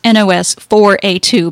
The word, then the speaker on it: NOS4A2: something by Joe Hill, and we're reading his latest, which is called Nosferatu, and that's spelled N-O-S-4-A-2. – I guess it's supposed to be pronounced N-O-S-4-A-2 because it's a license plate number.